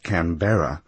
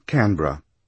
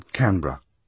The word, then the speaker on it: Canberra: Unfortunately, no one had thought to brief her, and she mispronounced it, placing the accent emphatically on the middle syllable, making it Canberra, rather than lightly on the first, as in Canberra, or even Canberra.